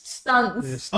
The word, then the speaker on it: Stunts: And number two is Johnny Stunts. No, Stints. – Stints? Johnny what? – Let me see how it's No, S-T-U-N-T-S. – Stunts.